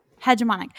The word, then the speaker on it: hegemonic: How do you pronounce the hegemonic. word that's spelled like that? – hegemonic.